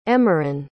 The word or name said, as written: emerin